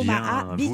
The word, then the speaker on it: Omaha Beach: Bien, Omaha Beach. – Vous l'avez bien prononcé.